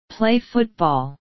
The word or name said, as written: play football